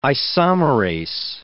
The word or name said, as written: isomerase